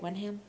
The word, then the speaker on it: Wenham: And David Wenham. Did I say it right? Wenham? – Yeah.